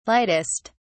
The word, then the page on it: lightest: British English